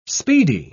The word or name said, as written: speedy